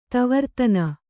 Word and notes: ತವರ್ತನ: kannada

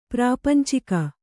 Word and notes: ಪ್ರಾಪಂಚಿಕ: kannada

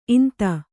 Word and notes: ಈಂತ: īnta

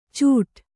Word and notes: ಚೂಡಾ: cūḍā

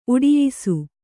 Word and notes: ಉಟಾಯಿಸು: uṭāyisu

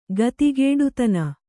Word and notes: ಗತಿಗೇಡುತನ: kannada

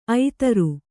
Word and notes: ಐತರು: Kannada

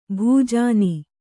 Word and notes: ಭೂಜಾನಿ: bhūjāni

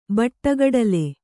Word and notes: ಬಟ್ಟಗಡಲೆ: Kannada

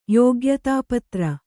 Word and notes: ಯೋಗ್ಯತಾಪತ್ರ: yōgyatāpatra

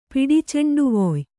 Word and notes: ಪಿಡಿಚೆಂಡುವೊಯ್: Kannada

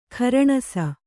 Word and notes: ಖುರಣಸ: khuraṇasa; Kannada

